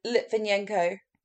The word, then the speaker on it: Litvinenko: Litvinenko.